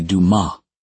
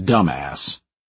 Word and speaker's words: Dumas: It's French, actually Dumas, like the famed author. Leo and I were born in Marseille. When we first moved to the USA and the town of Westbridge at age 8, our new friends thought it was ridiculously clever to pronounce Dumas as dumbass.